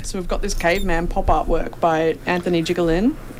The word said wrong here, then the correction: Jiglain: Am I pronouncing that right? – No, it's Jiglain.